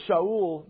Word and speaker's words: Shaul: First of all, notice that God says Shaul, that's the Hebrew way to pronounce his name, is going to be a chosen instrument.